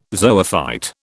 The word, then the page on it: zoophyte: British English